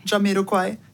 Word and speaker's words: Jamiroquai: Jamiroquai? – Ja, Quai. – Quai. Jamiroquai.